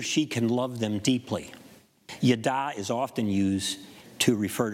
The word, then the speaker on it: yada: And the word for that is yada, Y-A-D-A, with an accent on the second A, Y-A-D-A, yada, yada.